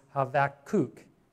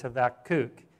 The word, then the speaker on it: Habakkuk: But it is Habakkuk is how you pronounce it. And you can see that here. It is. You listen to it. It's Habakkuk.